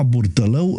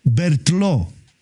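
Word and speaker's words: Berthelot: Nu-l chema Burtălău, îl chema Bertlo. Dar pentru că noi nu știam să pronunțăm Bertlo, îi spuneam Burtălău. Și toată armata română știa de domnul general Burtălău. Dar el era Bertlo.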